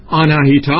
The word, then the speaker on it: آناهیتا: همچنان که آناهیتا هم که امروز میگیم در اصل آناهیتا هست، آ هست، نه آی کشیده نیست، آناهیتا هست.